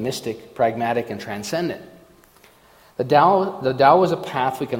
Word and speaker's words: Tao: And I picked out the chapter on Taoism today, which is spelled T-A-O, but it's pronounced Tao, as if it were a D.